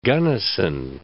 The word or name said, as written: Gunnison